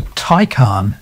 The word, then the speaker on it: Taycan: I might have said a Tay, but it's not that, is it? – No, it's a Taycan. – T-A-Y-N.